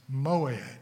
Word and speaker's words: moed: The Hebrew word for time appointed is moed, and it's spelled pretty much the way it's pronounced, M-O-W-E-D, moed.